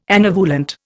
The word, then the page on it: anovulant: Indian